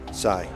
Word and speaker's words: Psy: Psy.